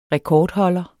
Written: rekordholder

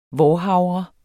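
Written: VÅRHAVRE

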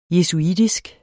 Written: jesuitisk

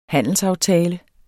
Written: handelsaftale

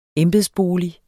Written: embedsbolig

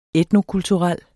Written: etnokulturel

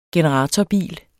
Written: generatorbil

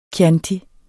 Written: chianti

